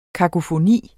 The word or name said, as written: kakofoni